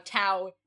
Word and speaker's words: Tao: Eventually, we're going to have to come to the point where we talk about how Tao is pronounced two different ways in this movie. – Is it? – Because the master does pronounce it correctly if you're talking about the Asian concept. Which is Taoism. Tao. Yeah. And he definitely says it more with a D several times.